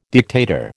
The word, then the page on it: dictator: American English